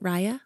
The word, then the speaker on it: Raya: How do you say it? Raya? – Yeah. – Raya. Okay.